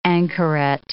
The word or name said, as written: anchoret